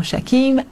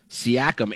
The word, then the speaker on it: Siakam: The Raptors' Pascal Siakim and also Joel Embiid. – That was the tough Siakim one right there. She butchered all three names. Yeah. Giannis and Embiid and Siakam, a.k.a. Siakim. See, I put that one on the producer of the CNN broadcast.